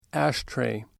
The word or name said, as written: ashtray